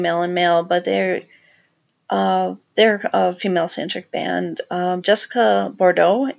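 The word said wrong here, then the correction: Boudreaux: is the lead singer. I'm sorry, I think I said that wrong. Oh, it's Boudreau? I'm sorry. I'm sorry, Jessica, I'm pronouncing that badly. But it's B-O-U-D-R-E-A-U-X.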